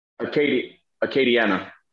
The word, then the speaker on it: Acadiana: How do you pronounce it? Acadiana? – Acadiana. – Acadiana.